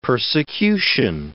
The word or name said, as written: persecution